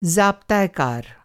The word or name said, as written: ज़ाब्ता-ए-कार